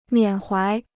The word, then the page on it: 缅怀: miǎn huái